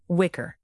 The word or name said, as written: wicker